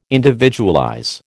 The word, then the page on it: individualize: American English